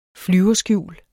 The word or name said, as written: flyverskjul